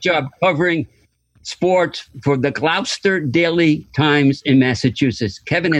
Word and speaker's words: Gloucester: You said Gloucester. That's how they pronounce it up there.